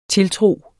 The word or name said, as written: tiltro